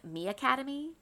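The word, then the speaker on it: MiAcademy: But this year, we're trying, I think it's pronounced Mi Academy. It's M-I-A-C-A-D-E-M-Y.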